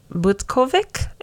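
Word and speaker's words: Butkovich: which was the case with 18-year-old John Butkovic, I think is how you say his name. – I think so. – Sounds right.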